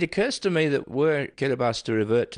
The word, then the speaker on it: Kiribati: Oh, it's just we don't have the S in our language, so we say Kiribati.